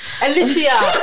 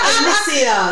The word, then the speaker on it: Alicia: Everyone calls me Alicia. It's Alicia. – Alicia. Alicia.